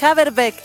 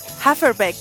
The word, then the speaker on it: Haverbeck: No es Haverbeck, es Haferbeck. Me voy a olvidar. Haferbeck. Bueno, debería escribirse con F entonces, pues. Me dice aquí Don Claudito que la B corta, o V, se pronuncia como F.